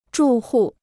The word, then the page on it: 住户: zhù hù